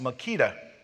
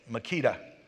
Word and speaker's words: Makeda: The people in Ethiopia, they like it because they have a queen that's in their history, and I think that her name is pronounced Makeda, like the drill, but not spelled that way. It's not spelled I-I-T-A, it is K-E-D-A, Makeda.